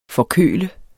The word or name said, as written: forkølet